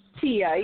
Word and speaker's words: Tia: Tia, yes. – Is it T-I-E? T-I-A? – T-I-A, yes.